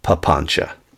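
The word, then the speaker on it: papañca: You know, papancha, just one of the favorite words of Pali. You should learn it. P-A-P-A-N-C-A. C is pronounced like C-H in Pali. P-A-P-A-N-C-A. Papancha.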